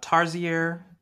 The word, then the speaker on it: Tarsier: So developed by Tarsier. Is that how you pronounce that? Anybody want to correct me on that one? Studios? – Yes, that's perfect pronunciation.